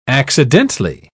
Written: accidentally

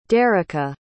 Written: derrica

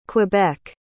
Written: quebec

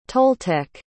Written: toltec

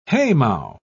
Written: haymow

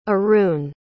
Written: Arun

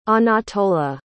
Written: Anatola

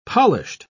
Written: polished

